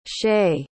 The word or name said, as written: shae